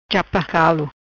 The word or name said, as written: Chapa Calo